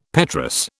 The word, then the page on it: petrous: British English